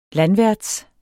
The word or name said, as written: landværts